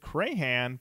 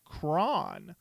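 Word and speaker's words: Crahan: Also, I watched an interview with Clown, and I always pronounced it Crayhan, but in it he said his last name is pronounced like Cron.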